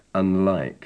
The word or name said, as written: unlike